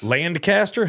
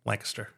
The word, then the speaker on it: Lancaster: Lancaster? – Lancaster. – Lancaster? – Lancaster. – Now, how do you say that properly? – Lancaster. – Lancaster. – Yes, not Lancaster. – Not Lancaster. – No. – Okay, because I think there was one.